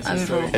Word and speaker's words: Abbey Road: Otra noticia que tiene que ver con lo que pasó esta semana: se cumplieron 45 años del histórico Abbey Road, ¿cómo lo pronuncié bien? Abbey Road, sí, Abbey Road.